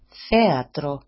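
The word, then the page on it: θέατρο: 8e-atro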